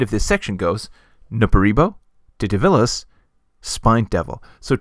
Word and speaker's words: Titivillus: Now, I will always say Titivillus, but by the rules of Latin pronunciation, it is Titivillus.